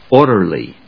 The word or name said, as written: orderly